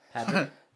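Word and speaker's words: Pádraig: Paddy is derived from the Irish Padraig. I butchered that pronunciation because I'm American. Padraig.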